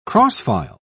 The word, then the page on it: cross-file: British English